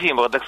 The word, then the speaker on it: зимородок: Обычно говорят зимородок, но все-таки про зоологи говорят зимородок, с ударением на первом слоге.